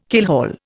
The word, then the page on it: keelhaul: Indian